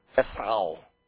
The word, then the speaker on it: Esau: Esau is how you say it in Hebrew.